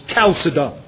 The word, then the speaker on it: Chalcedon: Chalcedon. My dictionary says the proper pronunciation, at least the anglicized pronunciation, is Chalcedon.